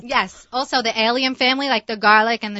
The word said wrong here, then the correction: allium: So for our listeners, allium. – A-L-L-I-U-M.